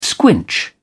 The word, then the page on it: squinch: British English